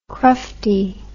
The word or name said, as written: crufty